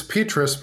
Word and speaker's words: Petrus: It's Petrus, but that's okay.